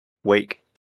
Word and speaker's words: Wake: And that game is, and I hope I'm saying it right, Alan Wick 2? – Wake. – There we go.